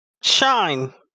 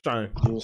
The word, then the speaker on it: Shein: I typed in Shein pronunciation, and she said Shine. – Oh, okay. Shine.